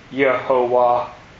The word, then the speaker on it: Jehovah: In Swedenborg's Latin Bible that he used, it would say Jehovah, you know, J-E-H-O-V-A-H, which in the Germanic pronunciation would be Yehovah, you know, the way, so that the J wasn't as hard as the way we pronounce it.